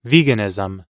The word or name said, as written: veganism